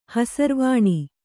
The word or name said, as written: ಹಸಿರುವಾಣಿ